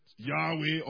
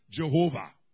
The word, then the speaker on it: Yahweh: That has been transliterated into Latin letters Y-H-W-H. And of course, the Hebrews never tried to pronounce it. But we have added vowels and we get Yahweh, or sometimes we say Jehovah.